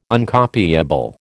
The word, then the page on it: Uncopiable: American English